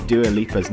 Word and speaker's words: Dua Lipa: Learn how to correctly pronounce Dua Lipa's name, aka it's not Dua Lipa, it's a D, not a J.